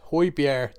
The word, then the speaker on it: Højbjerg: Yeah, Højbjerg.